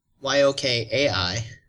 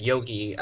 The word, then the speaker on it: yokai: Y o k a i. Oh, okay. Yeah, yeah. If there's an A in it, it makes it like a long I.